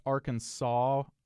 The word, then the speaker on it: Arkansas: Can you explain why it's pronounced Arkansas without the S sound?